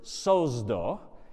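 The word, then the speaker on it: sozdo (σῴζω): The word is sozdo. If you were to spell that in English, it would be S-O-Z-O, sozdo.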